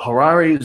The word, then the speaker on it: Harare: Harari, Zimbabwe, which, by the way, I actually went on to Google and typed in, how do you say Harari? And there's actually a video. You know, they have videos now where you just listen to them and it's someone saying the word three times. You actually get to hear how a word's pronounced. Man, the wonders of the internet. So Harare, Zimbabwe will be hosting its second WordCamp on November 4th, 2017 at Hellenic Academy.